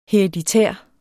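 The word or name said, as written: HEREDITÆRT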